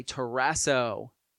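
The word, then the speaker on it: terasso (ταράσσω): The Greek word is the word terasso. Say terasso. Kind of rhymes with Ted Lasso.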